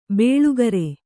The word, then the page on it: ಬೇಳುಗರೆ: bēḷugare